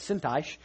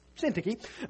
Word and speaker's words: Syntyche: and I actually had to look up how to pronounce that because I thought it was Syntyche, and it's not. It's Syntyche, according to the Bible readers that get paid to read the Bible.